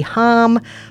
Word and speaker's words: Hom: a little something from Cody Hom, pronounced like homicide.